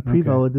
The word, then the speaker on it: Prevost: That's what they were saying. Prevost. Okay. So the S is silent, I guess.